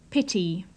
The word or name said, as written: pity